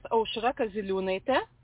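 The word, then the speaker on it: Aušra Kaziliūnaitė: And actually, the most interesting pronunciation of my name was in Bucharest. You know, my name is Ausra Kauzilinaite, but I was invited to read as Ashihura Kazili, and I really enjoyed the sound of it.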